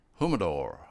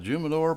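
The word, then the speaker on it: Jimador: I'm doing the El Jumador. I call it Jumador, but the J's in Spanish are actually H's, so I mispronounced it last week.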